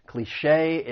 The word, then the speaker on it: cliché: Cliché, it has two syllables. The stress is on that second syllable, and that C-H-E with the accent mark sounds like che, cliché.